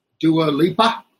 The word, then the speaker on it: Dua Lipa: His collaborator, Dua Lipa, did I say that right? – Yes.